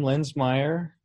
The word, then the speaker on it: Linsmeyer: Indeed, my last name Linsmeyer, spelled like uh, the town of Linz, but with an S instead of a Z.